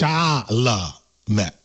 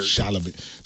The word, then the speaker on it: Chalamet: You never once managed in that segment to say it correctly. You said sham, you said charm, you said... – It's confusing, though. – Timothy what? – Well, now I know it's Chalamet. I know it's like the whole... – Okay, so yeah, well, that's three syllables, but let's put them all together into a word.